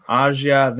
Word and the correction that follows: Asajj: Asajj Ventress.